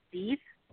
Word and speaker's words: Deeth: And he turns out to be tied to a case that is being pursued by my male main character, whose name actually looks like Death, but it's pronounced Deeth, and that's Deeth Bogart.